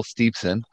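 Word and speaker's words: Steveson: So my number five was the same one as Kevin, but I'm going to actually say the name right. Kurt Angle and Gable Steepson. – Yeah, not Gableson.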